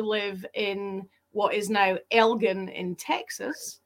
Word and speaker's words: Elgin: but all the rest of them are Elgins. – Right. – Elgin. But all the ones with a hard G like we have people from this area in their founding fathers of their town.